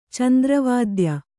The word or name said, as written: ಚಂದ್ರವಾದ್ಯ